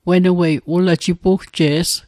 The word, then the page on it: jipugje's: Mi'gmaq